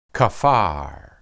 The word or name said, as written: kaphar